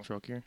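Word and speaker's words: Trokair: The flagstones of, I'm going to butcher this name, I always have, Trocaire. That's T-R-O-K-A-I-R. Trocaire. – Trocaire. – No, it's Tro. – Tro. Trocaire.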